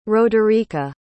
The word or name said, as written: roderica